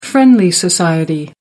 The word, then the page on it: friendly society: American English